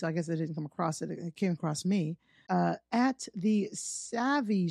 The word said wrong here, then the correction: Savoy: Show, Savoy, how you say it? – Savoy. – Thank you. The Savoy Show, okay.